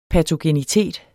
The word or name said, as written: patogenitet